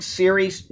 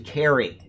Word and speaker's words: Cery: I've been saying Siri. His name is spelled C-E-R-Y. It's actually Carrie. He's in England, and I didn't know that they pronounce C-E with as a K.